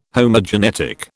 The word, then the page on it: Homogenetic: British English